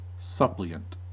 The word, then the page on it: suppliant: American English